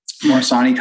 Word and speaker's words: Morsani: And you are uh, currently teaching medical school students at um, Morsani. Is that how you say it, Morsani? – Morsani College of Medicine, yeah.